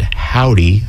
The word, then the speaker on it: Hovvdy: This first track is called Try, Try, Try, and it is by the band Howdy, although that is spelled H-O-V-V-D-Y, Havdi. So this is Try, Try, Try by Howdy off of their brand new album, Big World.